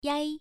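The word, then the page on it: yai: tone 1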